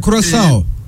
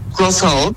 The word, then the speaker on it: Curaçao: Curaçao diye okundu. Doğrudur umarım telaffuzum. – Curaçao. – Curaçao.